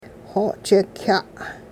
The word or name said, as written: xo'chik'-kya'